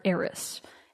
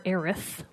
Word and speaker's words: Aeris: I've always called her Aeris, A-E-R-I-S. Some people call her Aerith with a T-H. I think odd. – That sounds stupid. So I say Aeris.